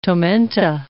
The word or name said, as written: tomenta